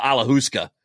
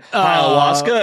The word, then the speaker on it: ayahuasca: You're like, you mean ayahuasca? And I'm like, alahuska. And you're like, no, ayahuasca, dipshit.